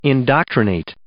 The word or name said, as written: indoctrinate